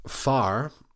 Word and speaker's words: fear: But so far, which is spelled F-E-A-R, is the Irish word for man.